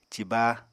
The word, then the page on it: ciba: Apatani